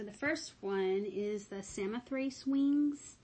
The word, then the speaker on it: Samothrace: I think that's how you say it. I'm sure in Greek it's like Samothrake or something like that, but I found Google pronunciations and they all say Samothrace, so it's the English version.